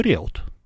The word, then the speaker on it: Риут: Командует полковник Иосиф Антонович Риут. – Да, вот так он. Он поляк, римско-католического вероисповедания. – Если поляк, то Риут. Предпоследний Риут. Слог ударения.